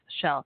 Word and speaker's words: Chelle: You also can join on Facebook, the Facebook group Coffee Talk with Shell. But my name, don't forget, is spelled C-H-E-L-L-E.